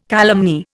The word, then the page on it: calumny: Indian